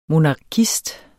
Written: MONARKISTEN

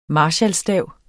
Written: marskalstav

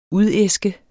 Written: udæske

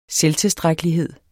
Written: selvtilstrækkelighed

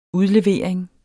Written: udlevering

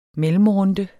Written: mellemrunde

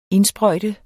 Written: indsprøjte

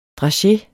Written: drageens

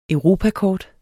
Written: EUROPAKORT

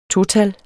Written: total

